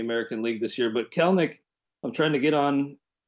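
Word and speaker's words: Kelenic: And it is pronounced Kelnick. Hate to be a stickler, but we hate a stickler, don't we, folks? But it is pronounced Kelnick. You can check it out on his baseball reference page. So maybe you can impress your friends before everybody else catches on and starts pronouncing his name correctly.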